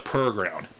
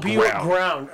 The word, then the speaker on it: Purr Ground: The Purr Ground. P-U-R-R. – Oh, Purr Ground.